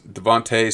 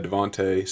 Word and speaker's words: DeVonta: Lastly, Devonta, Devontae Smith. I know it's spelled Devonta, but he goes by Devontae Smith.